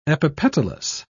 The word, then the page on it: epipetalous: British English